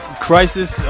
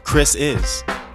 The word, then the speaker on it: Krisis: Krisis is spelled with a K, and my first name, well, my middle name is Chris. – All right. – So, Krisis. It's Krisis. Really what it spells.